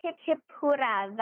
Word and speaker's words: Hip Hip Hurrah: but it's it looks like hip hip hurrah, but I think it's pronounced hip hip hoodah. – Yes. – Is that right? – Hip hip hoorah.